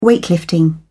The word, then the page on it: weightlifting: British English